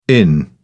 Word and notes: en: British English

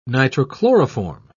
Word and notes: nitrochloroform: British English

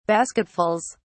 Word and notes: basketfuls: British English

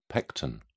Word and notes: pecten: British English

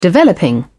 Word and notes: developing: British English

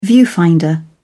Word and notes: viewfinder: British English

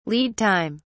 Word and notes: lead time: British English

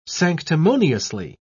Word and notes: sanctimoniously: British English

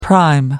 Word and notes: prime: American English

